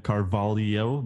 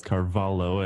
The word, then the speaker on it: Carvalho: And I called him Carvalho because it's C-R-L-V-H-O. But it's supposed to be pronounced as Carvalho.